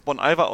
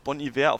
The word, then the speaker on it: Bon Iver: Also zum Beispiel, ähm, es kommt an einer Stelle das Lied von Bon Iver, Holocene, der wird äh, meines Erachtens übrigens Bon Iver ausgesprochen, obwohl das natürlich Bon Iver auf Französisch heißt, aber da das H fehlt, ist es cooler, Bon Iver zu sagen.